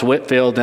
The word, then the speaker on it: Whitefield: I said Whitefield in the last service and somebody texted me and said, I know it looks like Whitefield, but it's actually pronounced Whitfield.